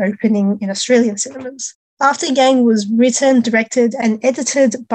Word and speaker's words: Yang: Now, moving on to another Asian-American sci-fi movie, A24 also has a film out called After Yang, or After Yang, if you want to pronounce it properly, like a Chinese person.